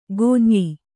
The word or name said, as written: ಕುಂಯ್